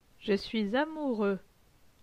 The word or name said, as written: Je suis amoureux